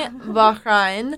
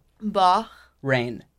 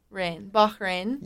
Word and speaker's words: Bahrain: If you could live in Miami, Bahrain, or, did I okay. – butcher that saying? – It's okay, it's just Bah. – Bahrain. Bahrain. – Yeah. – That was better, yes.